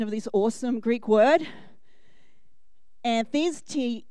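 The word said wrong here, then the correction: anthistēmi: I got it wrong. Try again. I even practiced too. Anthistami.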